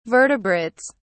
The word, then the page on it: vertebrates: British English